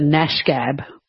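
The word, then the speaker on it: gnashgab: Or you, Gareth, might be a bit of a nashgab. And that starts with a G, a silent G.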